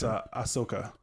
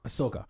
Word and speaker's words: Ahsoka: But back when we talked about, and I might be mispronouncing her name, but I think it's Akaso. – No, it's Ahsoka. – Ahsoka.